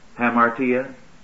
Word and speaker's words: hamartia: Hamartia? In English, you would spell it H-A-M-A-R-T-I-A, hamartia, and anomia, A-N-O-M-I-A.